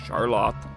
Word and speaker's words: Charlotte: It's spelt like Charlotte, but they say it's pronounced Charlotte.